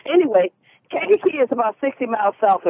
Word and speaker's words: Kankakee: Am I saying that right? – You know, it's Kankakee. – Kankakee.